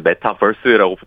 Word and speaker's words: 메타버스: 예, 메타버스의 그 버스는 뒤에 버스는 버스가 아니고 유니버스 할 때 그 버스입니다. V자가 들어가 있죠. – 네, 예. 맞습니다. 그 메타버스라고 보통 표현하는데. – 아, 발음 좋으십니다.